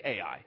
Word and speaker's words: Ai: My dad always likes to say it's the city you can't say without spelling it. That's how it's spelled, Ai, and that's the name of the city, Ai.